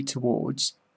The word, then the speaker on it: towards: Please remember, say towards, just as if it rhymed with boards.